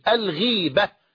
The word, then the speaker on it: الغيبة: إلى هنا الكلام يعرف عند النحويين وعلماء اللغة بالغيبة، والغيبة غير الغيبة، لأن بعض إخواننا ينطق الغيبة غيبة، وهذا لا يصح، بل الغيبة وهي ذكرك أخاك بما يكره بكسر الغين الغيبة، أما الغيبة هي ضده الحضور ضد الحضور أو نقيض الحضور.